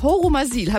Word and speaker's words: Horumersiel: Im schönen Örtchen Horumersiel. Habe ich das richtig ausgesprochen, Christian? – Ja, das ist absolut korrekt.